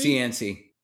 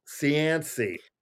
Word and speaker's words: Cianci: With some of your earlier you know, alterations of billboards and things, specifically a guy, how do you pronounce his name? Mayor Cianci? – Cianci. – Yeah. Cianci.